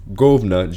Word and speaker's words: Guvnor: That's how it's spelled. Govna, G-U-V-N-O-R, McLean.